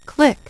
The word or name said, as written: click